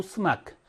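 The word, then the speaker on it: 스마크 (اسمك): 사 낙투부 스마크, 낙투부 스마크. 자, 원래 낙투부 이스마크 써야 되는데 지금 낙투부 스마크 이렇게 돼 있죠. 낙투부 스마크. 이 알리프는 발음하지 않는다 그랬어요.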